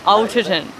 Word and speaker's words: Alderton: My name's Jess and I'm reading Ghosts by Dolly Alters. Is it? I'm going to start again. How do you say it? – Alterton. – Yeah. Alterton.